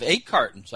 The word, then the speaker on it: Egg Cart N: We played off the name Cart N, and so it's actually spelled CART N, and so it's pronounced like egg carton, but it is just Egg Cart N.